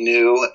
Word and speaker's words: Neu: But and Twitter, I just take the opportunity to share stuff here and there, and you look up MD New and they pretty much will all appear. It's either writer MD New or author MD New, and New is spelled N-E-U, not N-E-W. So N-E-U is how it's spelled.